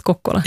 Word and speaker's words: Kokkola: orten som stavas Karleby men uttalas Kokkola.